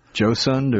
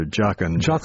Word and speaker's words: jocund: And just yesterday I had to look up whether to pronounce J-O-C-U-N-D, Josund or Jocund. – Jocund.